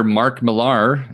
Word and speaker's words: Mark Millar: So next up on the dossier, comic book writer Mark Millar.